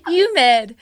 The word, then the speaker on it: humid: Why can't you say the H? – Humid. I don't know. – Why? – I know. That's why I can't say. – You're pronouncing it Y. – Humid. – No, there's an H.